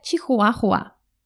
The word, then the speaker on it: чихуахуа: Номер два – это чихуахуа. Чихуахуа. Чихуахуа. По-русски мы произносим все буквы. Чи-хуа-хуа.